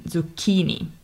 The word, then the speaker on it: zucchini: This time it's the middle syllable, so the chi in zucchini is spelled C plus H. So again, we get this hard K sound.